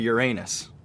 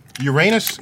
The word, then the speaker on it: Uranus: Okay, so Uranus is Uranus until you're like 8 years old. Then thereon, it's Uranus. So it's a very elementary school way to pronounce the word, especially since in the original Greek, it's spelled with an O. It's Uranus. Uranus, so not Uranus.